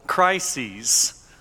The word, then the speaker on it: crises: As all of you are fully aware, our nation is in the midst of several crises. And yes, that is the proper way to say that word. I had to look it up. Crises is the plural of crisis, and we are in the midst of several.